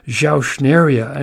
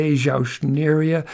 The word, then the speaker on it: Zauschneria: It's the former Jauschneria, a name I just love because I learned how to spell it. You know, Z-A-U-S-C-H-N-E-R-I-A, Jauschneria.